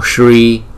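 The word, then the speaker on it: Śrī: Chữ S có dấu sắc chúng ta đọc là Sha, Namo Sri Mahadevaya, Namo Sri Mahadevaya.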